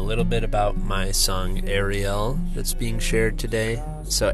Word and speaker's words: Ariel: So it's Hebrew, Ariel is how you say the Hebrew.